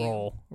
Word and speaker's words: Royal: It's pronounced by older Blount Countyans, Royal, as if the Y is silent.